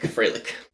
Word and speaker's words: Freilich: I don't think I pronounce it right, but it's Freilich. – Oh, Freilich. So not Frolic, Freilich.